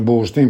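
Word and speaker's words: Burstin: Poi recentemente studi molto importanti sono questi di Sonnensker e anche questi di Burstyn, si pronuncia Burstyn perché è italiano, che insegna a Torino, L'invenzione del sans-culotte, un testo scritto in francese, e altri testi che ha scritto Burstyn.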